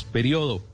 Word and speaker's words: periodo: Si le, si le marca la tilde en la I es porque seguramente lo pronuncia en cuatro sílabas. Periodo. Y si no le marca tilde seguramente lo pronuncia en tres sílabas. Periodo.